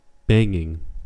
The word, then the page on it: banging: American English